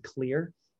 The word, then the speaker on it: Xlear: We we highly recommend Xlear, which is spelled X L E A R.